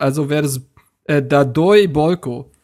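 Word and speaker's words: Pál: Und auch Palko wird anscheinend nicht Palko ausgesprochen, sondern Bolko. Bolko Dardoi.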